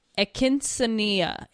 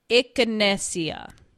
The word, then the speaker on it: Echinacea: We're going to go on to number 6, and this one is Echinacea. I totally butchered that. Echinacea.